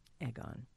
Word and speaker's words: Aegon: Aegon. – Okay. – It's spelled A-E-G.